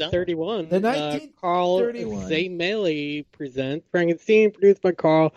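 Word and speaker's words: Laemmle: It's Carl Zemele. – Yes. – And You put two letters like that and expect me to know how to say it. – Let me tell you. – It's German.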